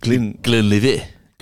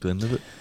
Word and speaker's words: Glenlivet: The whiskey, Glenlivet. – Oh. Founders Reserve. I think Glenlivet.